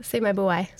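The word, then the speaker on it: Seimaibuai: A lot of the styles are based on the rice polish or semi-buai. Did I pronounce that right? – Semi-buai. – Semi-buai. Okay, perfect.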